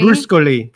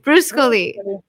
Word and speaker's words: Bruscoli: Bruscoli. Bruscoli.